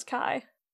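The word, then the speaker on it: chi: I think the Greek letter is key. – I thought it was Kai. – I don't know. I don't think so. – Because in the game, they're just like, yeah, it's usually pronounced different, but we just say key.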